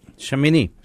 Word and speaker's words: Shemini: A-L-L-O-U. C-H-E. All right. In this portion, Leviticus 9 forward, the portion of the Torah we call Shemini. I can't do it. – Shemini. You said it right.